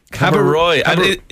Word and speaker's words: Cabo Roig: Cabo Roig. – Cabo Roig.